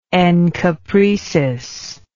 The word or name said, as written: encopresis